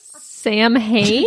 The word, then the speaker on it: Samhain: Back there, it was called Samhain, spelled S-A-M-H-A-I-N. Sorry. – Samhain?